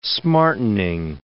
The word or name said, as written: smartening